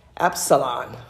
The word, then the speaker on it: Absalom: The spirit is also referred to as Jezebel and Asbalon. Am I saying it right? Absalom.